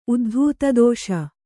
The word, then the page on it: ಉದ್ಧೂತದೋಷ: Kannada